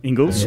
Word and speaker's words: Ingles: Ingles.